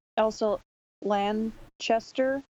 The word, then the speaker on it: Elsa Lanchester: So I didn't realize until after I saw the movie that Elsa Lanchester, is that how you pronounce it? – Yes.